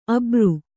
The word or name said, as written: ಅಬರು